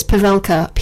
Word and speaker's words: Pavelka: It's Jesse, J-E-S-S-I-E. And his surname is, I hope I'm pronouncing it correctly, it's Pavelka, P-A-V-E-L-K-A.